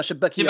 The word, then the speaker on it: Chebakia: Je dois prononcer mal. C'est comme tu vois ou pas? – Chebakia. – Kebakia, exactement. – Voilà.